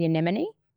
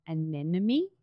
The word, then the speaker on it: anemone: Correct me if I'm not pronouncing this right, the anemone. – Or anemone? – Yeah, that one too.